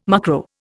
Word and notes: mucro: Indian

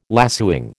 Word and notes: lacewing: American English

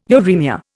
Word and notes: uremia: Indian

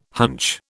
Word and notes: hunch: British English

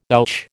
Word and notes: ouch: American English

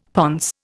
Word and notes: ponce: Indian

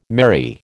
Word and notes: merry: American English

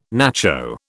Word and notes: nacho: British English

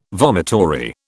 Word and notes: vomitory: British English

